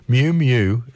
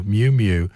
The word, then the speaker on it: Miu Miu: Mew Mew is spelled M-I-U-M-I-U. Mew Mew, it's not Japanese.